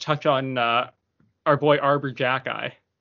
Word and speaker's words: Xhekaj: Pronounced Jacki, spelled Xhekaj.